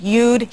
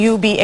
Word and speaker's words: you'd: A veces los hispanos no escuchan y no pronuncian la D después del nombre y dicen I be, you be, etc., pero la forma you be es incorrecta. Hay que pronunciar la D.